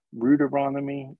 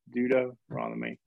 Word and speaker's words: Deuteronomy: How do you say Deuteronomy? Rudaronomy? Some kids said in youth group recently. I said, no, just remember that's the second giving. Deuteronomy.